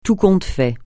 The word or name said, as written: tout compte fait